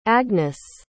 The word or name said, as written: agness